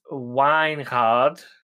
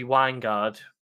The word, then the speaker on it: Wijngaard: I went this evening to do a little bit of research, mainly to try and figure out how to say his surname, which apparently in Dutch it's Wijngaard, Casper Wijngaard, but I thought it'd be Wijngaard, but because the IJ sound, but.